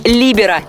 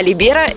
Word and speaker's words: либеро: Ну и еще, в волейболе, в отличие от других видов спорта, принято ставить ударение именно на первый слог – либеро, а либеро – это в футболе.